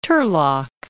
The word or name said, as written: Turlock